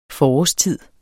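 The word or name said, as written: FORÅRSTIDER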